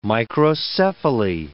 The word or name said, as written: microcephaly